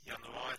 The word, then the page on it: januari: /janɵˈɑːrɪ/